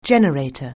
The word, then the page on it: generator: anglais